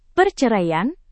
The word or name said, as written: perceraian